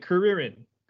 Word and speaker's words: Kuririn: I think Kuririn is moving up there because and you said it right. I love it. Kuririn.